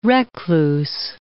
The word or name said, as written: recluse